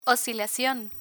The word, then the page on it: oscilacion: Spanish